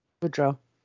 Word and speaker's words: Boudreaux: That same month, in October of 2019, Brandon, I'm going to butcher this last name. It's like very Cajun. Boudreaux. – Spell it. – That's not right. – Boudreaux. – Boudreaux. It's Boudreaux.